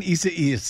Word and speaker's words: Isaias: Hurricane, how do I say this, Desi Doyen? – Isaias. – I see what you're doing. You're laughing at me. Hurricane Isaias. – There you go.